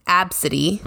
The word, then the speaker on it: Abcde: Her daughter's name is Absidy, but it's spelled ABCDE.